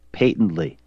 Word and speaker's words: patently: patently all the time. The correct pronunciation is patently. It is pronounced with a long A sound and not a short A sound, which you incessantly employ.